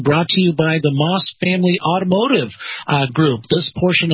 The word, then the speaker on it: Maus: And that's going to include Moss Acura and Moss Nissan. Now, Moss is spelled M-A-U-S. That's M-A-U-S.